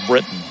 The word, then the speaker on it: Brittyn: Britton spells her name B-R-I-T-T-Y-N, so I'm assuming you pronounce it Britton.